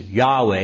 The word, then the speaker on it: Yahweh: Yahweh, it would be the Hebrew pronunciation of that.